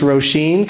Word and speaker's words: Roisin: And so everywhere you go, you have to tell people it's pronounced Roisin, because it's certainly spelled, it's tricky. – Irish, it's an Irish name. So it's spelled like raisin. But Roisin Conaty, yeah. – If raisin was spelled with an O.